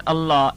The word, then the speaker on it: Allah: Because the word Allah, if preceded with a Dhamma and a Fatha, is full mouth. If preceded with a Kasra, that means Zir, then it is pronounced lightly.